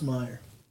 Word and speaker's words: Meijer: Why is it pronounced Meyer? – I don't know. It's a fair question. – You look at that son of a bitch, and It's like, a J.